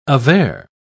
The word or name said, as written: aver